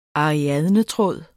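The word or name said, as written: ARIADNETRÅDE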